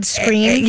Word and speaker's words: Skrein: Is it really Ed Skrein? – Yes, let me spell it. Donnie, look it up while. S-K-R-E-I-N.